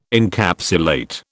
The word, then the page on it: Encapsulate: British English